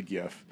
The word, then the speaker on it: GIF: It's supposed to be pronounced GIF, which makes no sense to me, and I think that's just completely idiotic. – And he should have spelled it with a J. – Absolutely. It's a soft J, like Yogg.